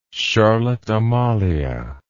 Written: charlotte amalie

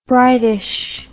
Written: brightish

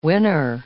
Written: winner

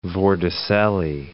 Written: vorticellae

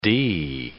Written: d

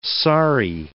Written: sari

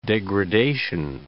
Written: degradation

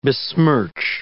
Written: besmirch

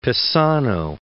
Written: Pisano

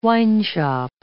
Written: wineshop

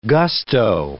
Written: gusto

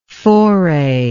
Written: forayer

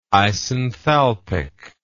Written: isenthalpic

